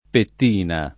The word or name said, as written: pettina